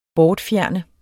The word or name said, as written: bortfjerne